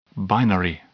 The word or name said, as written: binary